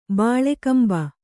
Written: ಬಿಳ್ಕುಂಬೆ

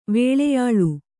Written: ವೇಳೆಯಾಳು

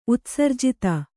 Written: ಉತ್ಸರ್ಜಿತ

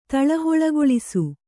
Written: ತಳಹೊಳಗೊಳಿಸು

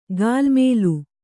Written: ಗಾಲ್ಮೇಲು

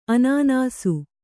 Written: ಅನಾನಸ

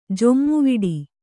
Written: ಜೊಮ್ಮುವಿಡಿ

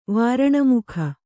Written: ವಾರಣಮುಖ

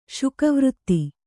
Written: ಶುಕವೃತ್ತಿ

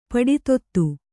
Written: ಪಡಿತೊತ್ತು

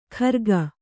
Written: ಖರ್ಗ

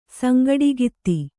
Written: ಸಂಗಡಗಿತ್ತಿ

